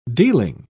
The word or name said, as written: dealing